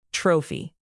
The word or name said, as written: trophy